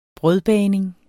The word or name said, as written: bagning